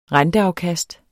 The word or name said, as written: renteafkast